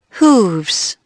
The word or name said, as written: hooves